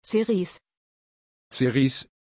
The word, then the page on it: cerise: German